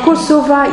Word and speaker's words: Kosova: Alors, Kosova, ils tiennent beaucoup, hein, à cette prononciation, hein, puisque c'est la prononciation en albanaise.